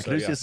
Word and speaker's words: Caduceus: Is that how you pronounce it? Caduceus? Yeah, Caduceus' staff necklace around his neck as well,